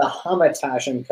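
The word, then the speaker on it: hamantaschen: Well, I'm going to start off by saying that that picture is not a proper representation of the Hamatashin cookie. That is the proper pronunciation.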